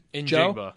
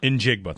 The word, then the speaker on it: Njigba: Njigba. – Njigba, thank you.